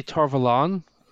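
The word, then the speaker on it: Tar Valon: I say Tar-Valon because I pronounce it as close to Avalon.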